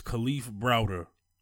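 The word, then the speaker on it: Kalief Browder: I said it right the first time, said it wrong the second time. It's Khalif Browder.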